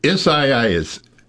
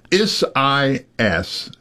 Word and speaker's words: Isaias: Isaias. Isaias. Isaias.